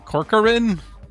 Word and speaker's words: Corcoran: Barbara Corcoran. Corcoran? Is that how you say it? – Yeah.